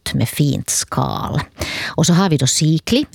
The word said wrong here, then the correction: Siglinde: eller Siglinde, hur den uttalas, Siglinde.